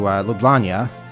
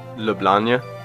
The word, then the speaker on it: Ljubljana: How do you pronounce it? – Ljubljana. – Isn't that what I just said? Ljubljana? I thought you had like a Y in there or something.